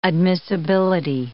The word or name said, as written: admissibility